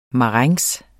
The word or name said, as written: marengs